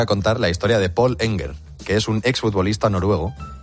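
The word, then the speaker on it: Pål Enger: Es que como es noruego, la A tiene este circulito encima que es una letra pues nórdica que se pronuncia como O. Es Paul Enger.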